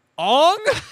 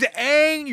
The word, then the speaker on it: Aang: Ong? His name's Aang, you fuck. Ong. – Not even that, like. – Yeah, it's literally pronounced Aang.